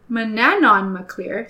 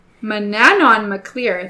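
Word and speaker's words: Manannán mac Lir: Manon MacLear. I said that wrong. Mananon MacLear. See what I mean with these Irish pronunciations? – Ooh, missed an entire syllable in there. – Okay. Rewind. Mananon MacLear, the sea god, is the king in question this time.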